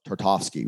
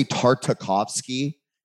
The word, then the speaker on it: Tarkovsky: If you go back in time, especially like, for example, Eugenia, you love Tarkovsky, right? – Right. – That's the right way to pronounce Tarkovsky. – Tarkovsky. I used to say Tartakovsky, and I was like, it makes him sound like a sauce that comes with like fried fish.